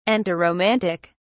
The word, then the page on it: antiromantic: British English